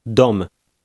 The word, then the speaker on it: Dom: Listen and repeat number 9, paying attention to the short Polish O. – Dom.